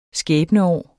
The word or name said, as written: skæbneår